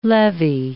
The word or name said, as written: levy